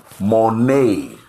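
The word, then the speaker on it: monai (μοναί): The word mansion there in Greek means monai, pronounced M-O-N-A-Y. Monai, an abode.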